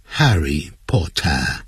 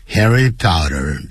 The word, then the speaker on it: Harry Potter: T-I-T-L-E 也就是标题，那这个标题是什么呢？ Harry Potter。如果是英国人的念法叫 Harry Potter，好，他们是这样念的， Harry Potter 就是美式的发音，哈利波特我们大家应该都熟悉了。